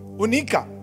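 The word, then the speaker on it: onycha: Then there is this onica. That's how you pronounce it. Okay, onica. It actually is.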